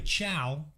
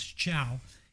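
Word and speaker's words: Thiaw: Now we have Ciao, which is Thiao with the T you pronounce Ciao.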